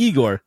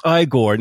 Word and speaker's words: Igor: And then Frankenstein goes, "You must be Igor." "No, it's pronounced Igor."